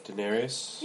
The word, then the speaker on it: Daenerys: Daenerys? How are you saying this? – Daenerys? – Yeah. – Am I saying it wrong? – Holy shit. No, Kate, you're fine.